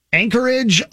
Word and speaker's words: Anchorage: I'm not sure I'm saying this correctly. Anchorage, Alaska? – You are saying that right.